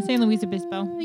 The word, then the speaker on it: San Luis Obispo: Yeah, San Luis Obispo.